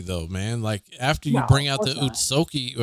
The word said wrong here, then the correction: Otsutsuki: The Otsutsuki family.